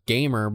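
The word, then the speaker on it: GAMR: And then there's this ETF, and you would actually pronounce the ticker if you tried to pronounce the ticker as a word, gamer, but it's G-A-M-R, and it is the Wedbush ETF Video Game Tech ETF.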